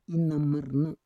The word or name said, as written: inamarna